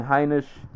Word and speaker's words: Heinisch: Uh, moving on, continuing with the theme of making debuts, uh, Ian Heinisch, uh, that's how you say it, uh, made his UFC debut against Cesar uh, Ferreira.